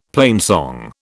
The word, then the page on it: plainsong: British English